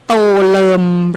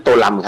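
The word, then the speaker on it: Tô Lâm: โตเลิมหรือโตลำคะโตลำครับผมอยู่ที่เวียดนามเรียกท่านโตลำครับ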